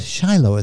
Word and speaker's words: Shiloh: I wrote the name of it because it's spelled like it would be pronounced Cilio, but it's Shiloh is how it's pronounced.